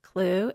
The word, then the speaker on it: Kloo: Okay, you can email me at Dana Clue, and my last name is spelled K-L-O-O. – D-A-N-A-K-L-O-O.